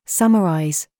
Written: STRUT

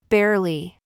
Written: BOB

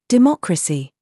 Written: RAW

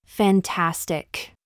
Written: FIFE

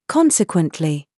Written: SAUCE

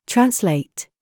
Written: FACE

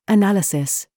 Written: THIS

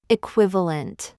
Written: VERVE

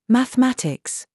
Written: SAUCE

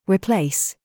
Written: PIPE

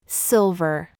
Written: VERVE

